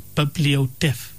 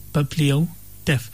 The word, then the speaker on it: bibliotaph: is the word bibliotheph. Bibliotheph. Bibliotheph is spelled B-I-B-L-I-O-T-A-P-H.